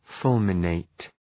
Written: fulminate

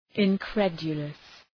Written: incredulous

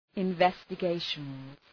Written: investigations

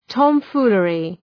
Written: tomfoolery

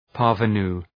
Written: parvenu

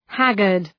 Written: haggard

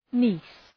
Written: niece

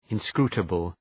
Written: inscrutable

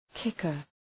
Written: kicker

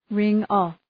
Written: ring-off

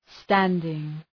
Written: standing

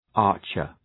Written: archer

